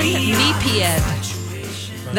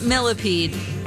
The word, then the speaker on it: Millepied: Benjamin Millepied. That is how you say it, not millipede.